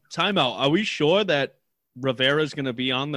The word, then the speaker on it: Rivera: I think Haskins is going to have a good few games, and Ron Rivera, is Ron Riev can't even say his name properly. – Rivera.